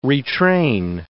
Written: retrain

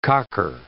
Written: cocker

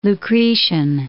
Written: Lucretian

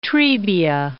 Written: Trebia

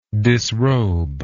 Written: disrober

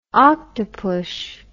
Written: octopush

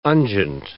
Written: unguent

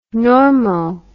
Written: normo

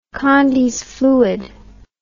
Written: condys fluid

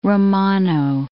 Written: Romano

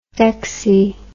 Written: dexy

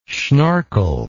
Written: schnorkle

